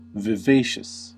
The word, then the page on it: elohopea: /ˈe̞lo̞ˌho̞pe̞ɑ/; /ˈe.loˌho.pe.ɑ/